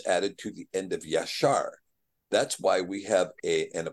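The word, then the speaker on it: Yashar (ישר): Now, Yod, Shin, Resh is very clearly and very obviously, and Strong's will tell you, is pronounced Yashar.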